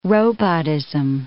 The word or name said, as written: robotism